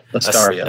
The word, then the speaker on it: Astaria: Astaria.